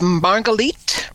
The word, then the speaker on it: Marguerite: It's Marguerite, rhymes with sweet, which I hope people will think this story is a sweet story.